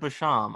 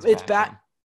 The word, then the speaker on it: Basham: I think it's Basham. – Yeah, Bashomp definitely doesn't sound right.